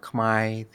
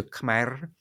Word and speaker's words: Khmer: First, so this term Khmer or Khmai, these are just different pronunciations of the same word in the Cambodian language. It's as pronounced in Cambodia in most dialects, it's pronounced Khmai, and that pronunciation is often favored in Cambodian diaspora communities. In other dialects, particularly dialects spoken in of Cambodian spoken in Northeast Thailand, the pronunciation may be closer to Khmer. And in the spelling, in the language itself, there is a ro, there is an R at the end.